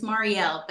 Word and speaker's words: Mariel: Yes, it's Marielle, but that is totally Yes, I have to remember that L, Marielle.